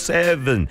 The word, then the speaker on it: Seven: BTS 정국이 발표한 솔로곡 Seven, V 발음이죠. Seven이 빌보드 핫100 차트에서 1위에 올랐던 그날이었습니다.